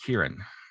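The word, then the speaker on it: Ciarán: It's pronounced Kieran. Kieran. Yeah.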